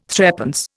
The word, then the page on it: threepence: Indian